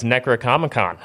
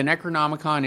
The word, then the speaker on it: Necronomicon: the Evil Dead's Necronomicon.